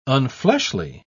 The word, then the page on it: unfleshly: British English